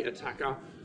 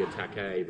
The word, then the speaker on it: Attacker: Like, it comes from a French word, and if you were to pronounce it correctly, it should be attaque, but we're Australian, so we just kind of Australianize it and say attacker.